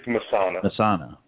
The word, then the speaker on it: Messana: It's Masana.